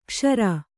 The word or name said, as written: ಕಶೇರು